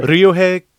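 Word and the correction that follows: Ryuhei: Kitamura. – Very close, yeah. Uh-huh. Yuhei. Yeah.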